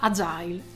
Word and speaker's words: agile: Infatti il termine si pronuncia agile e fa riferimento ad un approccio che prevede il coinvolgimento del cliente in tutte le fasi della progettazione di un corso e-learning.